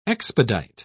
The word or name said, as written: expedite